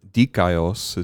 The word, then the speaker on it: dikaios: What I see in this text, looking at the Greek right now, is that being righteous, dikaios is how you say righteous.